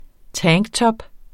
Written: tanktop

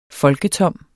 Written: folketom

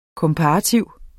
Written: komparativ

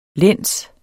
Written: læns

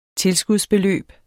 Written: tilskudsbeløb

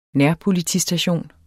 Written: nærpolitistation

